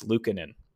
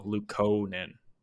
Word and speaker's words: Luukkonen: But, and also because I had always said Ukko-Pekka Luukkonen. I had always like had that long U. And it's Luukkonen. Or I guess long O, Luukkonen.